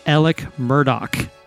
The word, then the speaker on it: Murdaugh: His name is spelled Alex Murdaugh, but it's pronounced Alec Murdoch.